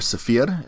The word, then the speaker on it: Cypher: So moving over to the runner side, first one on the list is Cypher, or Saphir, if you want to pronounce it more accurately, I suppose.